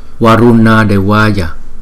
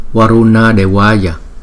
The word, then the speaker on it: Varuna Devaya: Thủy Tiên Phật chúng ta sẽ phát âm là Varuna Devaya, Varuna Devaya, Varuna Devaya, Varuna Devaya, Varuna Devaya.